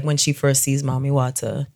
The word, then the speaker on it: Mami Wata: Mami Wata. Did I say it right this time? – Yes, you did.